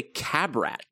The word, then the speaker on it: Qabbrat: It is called a Cabrat. It's spelled Q-A-B-B-R-A-T.